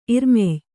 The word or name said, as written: ಇರ್ಮೆಯ್